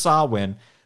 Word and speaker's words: Samhain: So okay, so it's pronounced Sawin.